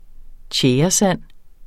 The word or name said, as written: tjæresand